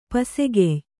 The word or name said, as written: ಪಸೆಗೆಯ್